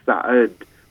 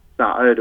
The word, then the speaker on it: Sa'ud: So I had an unusual Arabic name called Sa'ud, but no one could pronounce my name Sa'ud. It was Sad.